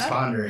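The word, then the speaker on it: Pend Oreille: It looks like Pendoriel or whatever, but it's Pendere.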